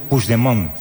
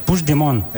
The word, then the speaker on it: Puigdemont: Me has preguntado antes, ¿cómo se dice este nombre? – Sí, voy a intentar. ¿Ah? – No, Puigdemont. – Puigdemont. – Vale. – Puigdemont. – Eso es. – Sí, lo dices como francés, así.